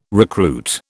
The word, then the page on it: recruit: British English